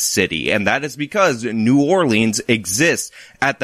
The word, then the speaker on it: New Orleans: New Orleans, or Nolens, if you're a local and you like the local pronunciation, is one of America's great cities.